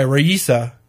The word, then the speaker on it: Raisa: It's spelled R-A-I-S-A, Raisa.